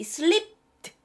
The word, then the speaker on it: slipped: It slipped! 발음이 들어가야 돼요. It slipped my mind! 다시 한번. It slipped my mind. 트 발음 내시면 돼요. 여기서 트 발음 내세요.